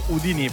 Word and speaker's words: Houdini: sua maestà la dottoressa Dua Lipa, la sua Houdini, questa è la pronuncia che usa lei, io avrei detto Houdini, però insomma rientra nella metrica e quindi decide assolutamente lei.